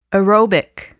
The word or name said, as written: aerobic